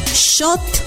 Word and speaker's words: xot: Para decir comal, decimos shot. Shot se escribe X-O-T.